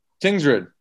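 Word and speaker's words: Tingsryd: Okay, pronounce the name of the team you played for. Tingards? – Tingsrid. Tingsrid in Sweden.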